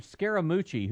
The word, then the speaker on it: Scaramucci: And now he's got a spokesperson named Scaramucci, who looks like...